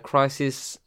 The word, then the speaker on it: Khrysis: You know, Crisis, part of the... it's spelled K-H-R-Y-S-I-S, by the way.